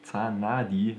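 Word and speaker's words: Zanardi: Das ist auch jetzt schon wieder etwas länger her, aber der Unfall von Alessandro Zanadi, so wird er ausgesprochen.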